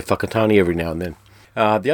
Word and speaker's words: Whakatane: WH sounds are pronounced with an F in New Zealand, so in the map it looks like Whakatane, but it's actually Whakatane.